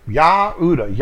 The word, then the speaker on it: Yehuda: Yehuda, Yah be praised. And they've twisted that and they've added a J onto it. Now it's pronounced Judah in the English, which is completely wrong.